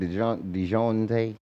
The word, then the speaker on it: Dejounte: DeJounte Murray.